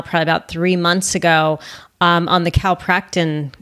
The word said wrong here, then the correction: Calprotectin: What is it? Calprotein. Calpractin. – Calprotectin. – Calprotectin. I always forget how to pronounce it.